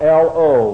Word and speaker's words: lo: And then you have an unpronounced aleph, which is simply spelled L-O, lo, lo shuv, is what it says in the Hebrew, and it simply means not to return or not to go back.